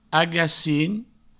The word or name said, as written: agacin